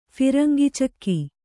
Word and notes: ಫಿರಂಗಿಚಕ್ಕಿ: phirangi cekke

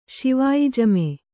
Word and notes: ಶಿವಾಯಿಜಮೆ: Kannada

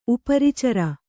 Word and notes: ಉಪರಿಚರ: Kannada

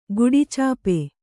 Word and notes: ಗುಡಿಚಾಪೆ: guḍi cāpe

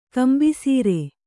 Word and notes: ಕಂಬಿಸೀರೆ: kambisīre

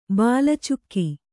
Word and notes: ಬಾಲಚುಕ್ಕಿ: kannada

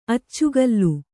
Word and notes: ಅಚ್ಚುಗೋಲು: accugōl

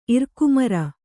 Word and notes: ಇರಕುಮರ: irakumara; kannada

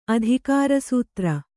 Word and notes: ಅಧಿಕಾರಸೂತ್ರ: adhikārasūtra

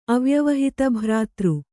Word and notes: ಅವ್ಯವಹಿತಭ್ರಾತೃ: Kannada

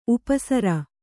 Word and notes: ಉಪಸರ: upa sara

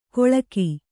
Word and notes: ಕೊಳಕಿ: koḷaki

